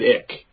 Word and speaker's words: Icke: But in any event, so the next person that he mentioned was David Ick. You know, I think we're calling him Icky, or some people call him David Ike. It's his last name is spelled I-C-K-E.